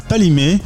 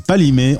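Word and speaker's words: Kpalimé: Alors justement, allons à, Palimé. Ça s'écrit avec un K et un P, mais on dit pas le K. Donc c'est Palimé. On est en plein centre du Togo.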